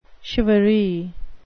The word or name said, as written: charivari